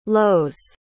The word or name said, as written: lows